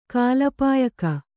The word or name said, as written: ಕಾಲಪಾಯಕ